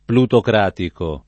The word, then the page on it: plutocratico: lingua italiana